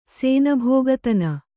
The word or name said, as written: ಸೇನಭೋಗತನ